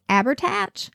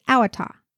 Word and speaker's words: Abhartach: As an aside, the name is spelled A-B-H-A-R-T-A-C-H, so it looks like it's pronounced Abertach. But I consulted the Google machine, and it told me it was Awatha.